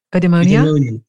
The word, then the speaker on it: eudaimonia: You were exploring the meaning of, I don't know if I say it properly, eudaimonia. Eudaimonia? – Yeah, that's right.